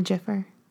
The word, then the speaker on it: gif: I noticed you said gif with a hard G. – I do. – Mm. – Are you a gifer? – I'm a jiffer. – Oh, jiff. See, that to me, though, is like the peanut butter. J-I-F-F. – I know. The soft G is just weird.